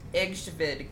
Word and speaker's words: Egtved: So she is spelled EGTVED. – One more time, girl. – EGTVED. Egsved girl.